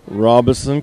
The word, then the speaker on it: Robeson: Fuqua Varina, Robeson County.